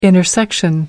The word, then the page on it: intersection: American English